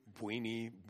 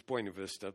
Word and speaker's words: Buena Vista: They pronounce it some horrible Texas pronunciation up there. Buini, Buini, I don't know. Buny, Buny, that's how they say it. Anyway, it's, it's Buena Vista.